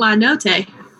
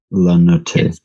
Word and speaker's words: La Notte: So, I say it La Notte. – Yeah. – In Italian. Yeah. – Okay. – You could pronounce that E. – La Notte.